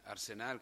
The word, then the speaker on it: Arsenal: Allora, un rapido inquadramento di Arsenal. Come vedete l'accento è sulla seconda A perché è alla Veneta,